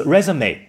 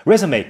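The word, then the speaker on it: résumé: 名词 resume 的发音呢非常特别，因为它是来自一个法语的词汇，所以严谨的写法应该是在两个 e 字母上面加上重音符号。 resume 跟 CV 的意思是一样的，都是指简历、履历。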